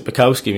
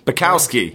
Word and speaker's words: Bukowski: Yeah, you have, although I pronounce it Bukowski myself because I'm not actually Polish and I like to sound like I'm from New York.